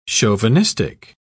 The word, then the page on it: chauvinistic: British English